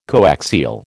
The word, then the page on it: coaxial: American English